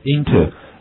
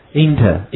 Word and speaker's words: into: No parking， no parking， OK，好，跟读一下 we have it， no parking， no parking， no parking， no parking， yes， OK，好，进入 we have it， into， into， into，啊，这个词可以有两种读音，好， we have it， into， into， into。 Inter, inter, inter。